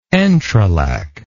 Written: entrelac

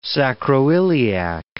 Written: sacroiliac

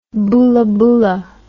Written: boolaboola

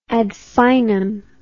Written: ad finem

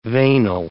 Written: veinal

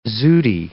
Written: zooty

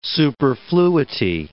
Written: superfluity

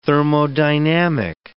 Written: thermodynamic

